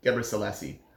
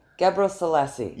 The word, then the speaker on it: Gebre Selassie: How do you say his surname? – Gebre Selassie. – Gebre Selassie. – Yes. – Gabriel Selassie. – It took me a while, don't worry.